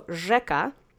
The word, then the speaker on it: rzeka: So, rzeka, accent is on the first syllable.